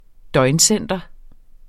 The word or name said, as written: døgncenter